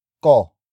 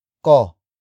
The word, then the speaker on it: ko: The next rule about the ba particle is that it can be placed right after the pronoun ko. Ko. Ko. Ko is spelled as K-O.